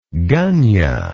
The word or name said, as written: gunyah